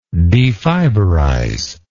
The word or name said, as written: defiberize